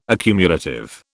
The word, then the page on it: accumulative: British English